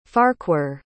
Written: farquhar

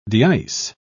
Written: deice

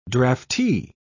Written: draftee

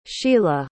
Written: shela

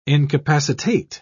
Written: incapacitate

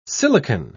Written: silicon

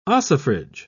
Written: ossifrage

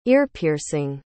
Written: ear-piercing

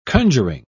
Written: conjuring

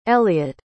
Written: eliot

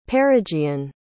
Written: perigean

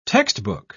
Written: textbook

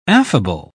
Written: affable

